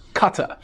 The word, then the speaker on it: Qatar: By the way, that's actually how it's pronounced. It's not Qatar or Qatar, it's Qatar. The actual Arabic way is a little bit more like Qatar.